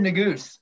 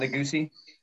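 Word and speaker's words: Nuguse: Jared Neguse, which that's the way you say his name, right? – Yard, I'm not sure. – It's not Neguse-y.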